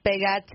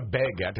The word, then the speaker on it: baguette: You went long A, it's supposed to be a short A. It's not a baguette, it's a baguette.